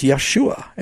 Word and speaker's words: Yeshua: in Hebrew, it's Yeshua. And I agree with that. That is the right pronunciation in Hebrew, or close as we know.